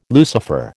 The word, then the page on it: Lucifer: American English